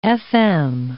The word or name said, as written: FM